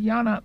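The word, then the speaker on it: jñāna: He is related to the Sanskrit Janana, pronounced Yana, meaning gnosis.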